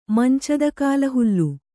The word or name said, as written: ಮಂಚದಕಾಲುಹುಲ್ಲು